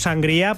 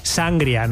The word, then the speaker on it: sangría: Bueno, vosotros decís sangria, ¿no? Lo pronunciáis mal. – Sangría. – Decís sangría. – Sangría. – No, pero es que le ponéis ahí el acento en la A, en la primera A.